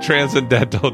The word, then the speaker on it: transcendental: Rich maple syrup, roasted coffee, and velvety chocolate meld together in what can only be described as a transcendental. Thank you. I was putting the emphasis on the wrong syllable. The transcendental drinking experience.